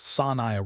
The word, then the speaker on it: Sanya: Sanya Richards-Ross, pronounced Sanaya Richards-Ross, the Olympic sprinter, takes this same multi-year approach to her career.